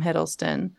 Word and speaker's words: Hiddleston: Then they broke up, and then she started dating Tom Hiddleston, and then she broke up with Tom Hiddleston.